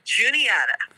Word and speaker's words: Juniata: Juniata.